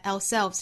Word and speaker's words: ourselves: Truly 乍一听应该有一个 really 这样的音后面的 help 和 ourselves 中间是可以停顿一下的 help ourselves 强调在 ourselves 这个词 ourselves 重读在后面这个音节上前面的 our 我们可以读成 our 也可以读成一个单元音的 a 再加后面半部分读成 ourselves 或者是读成 ourselves 也是可以的好我们来用非常慢的速度用英音来读一下这句话 It's only by helping others that we can truly help ourselves.